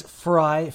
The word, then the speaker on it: Frei: It is Frei phonetically because it's half of my last name, which is Freiheit.